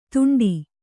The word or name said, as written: ತುಂಟಿ